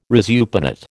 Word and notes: resupinate: American English

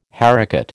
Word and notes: haricot: American English

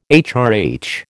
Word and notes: HRH: American English

